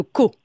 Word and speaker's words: Caux: Et donc vous travaillez au village voisin de Caux. – Oui, voilà, C-A-U-X. Oui. – On ne prononce pas le X.